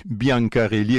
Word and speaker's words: Biancarelli: par Franck Biancarelli. Biancarelli, exactement comme ça se prononce, qui est au dessin.